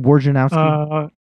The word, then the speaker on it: Wojnarowski: Adrian Wojnarowski. Wojnarowski. How do you say his name? – Yep. – Adrian Wojnarowski.